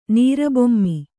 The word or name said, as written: ನೀರಬೊಮ್ಮಿ